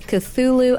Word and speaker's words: Cthulhu: So and as I look at the name of the play, I have wrestled with how to pronounce that, so I'll let you pronounce it. – It's Cthulhu, a Puppet Play.